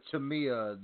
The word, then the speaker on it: Tamia: Really love that jam both ways from Donald Glover and Tamina herself. – Not Tamina Snuka, Tamia, the Mrs. Grand Hill. – I've been getting her name wrong this entire time.